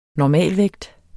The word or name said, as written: normalvægt